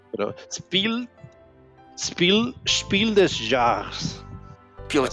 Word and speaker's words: Spiel: eh, Spiel creo que se, pronuncia Spiel, así como. Spiel, ajá, Spiel, sí, eso sí, eso sí es la parte alemana, eso ah. no hay pedo, güey. Pero el otro es Jars, es J-A. J-A-H-R-E-S.